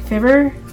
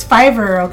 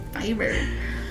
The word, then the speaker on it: Fiverr: But it was funny because he's like, Fibber? I mean, maybe it is Fibber and I'm saying it wrong. – I don't know. – But it's Fiverr, okay? – Fiverr.